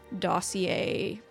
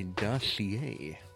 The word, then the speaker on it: dossier: With it is a little dossier pamphlet that Elian told you was there. – Okay. – Which I will grab now. – Okay. A dossier. – Or, pronounced dossier, actually.